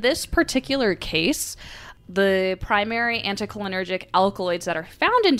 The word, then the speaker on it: anticholinergic: The plant contains potent tropane alkaloids, all of which are classified as anticholinergics,